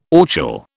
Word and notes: orchil: British English

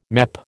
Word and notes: MEP: American English

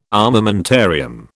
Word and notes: armamentarium: British English

